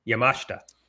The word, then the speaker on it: Yamashita: You know, I say that name all the time, and I just said it wrong. It's Yamashita.